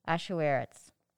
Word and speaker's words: Ahasuerus: Queen Vashti has also made a feast for the women in the royal house of King Ahasuerus.